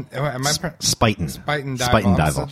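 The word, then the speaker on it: Spuyten Duyvil: She asks, Allison Hamlin, the deputy director of Heritage Radio Network, Sputin Dival open. – Spuiten Dival. So the U and the Y is pronounced like an I.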